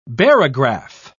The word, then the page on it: barograph: British English